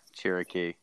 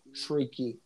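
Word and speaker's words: Chriqui: I can only imagine $100 million of that was to see Emmanuel Cherokee. – Oh, it's actually pronounced Shrieky.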